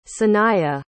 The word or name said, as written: sanaya